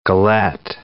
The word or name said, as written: galette